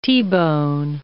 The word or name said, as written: T-bone